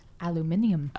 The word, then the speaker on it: aluminium: And as the Brits would say, what do they say? – Aluminium. – Aluminium. That's right.